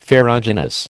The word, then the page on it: ferruginous: American English